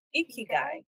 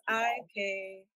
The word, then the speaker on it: Ikigai: I'm going to, how do you say the Ikigai? Yes. Ikigai. – Ikigai.